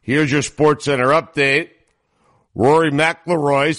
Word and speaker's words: McIlroy: McIlroy.